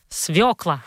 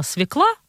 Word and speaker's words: свёкла: Правильно говорить «свекла». А просторечная форма «свекла» появилась, потому что, к сожалению, перестали ставить точки над буквой «ё» и принялись все читать неправильно и говорить неправильно. И это обидно, потому что «свекла» вкусная и полезная заслужила, чтобы мы ставили ударение правильно.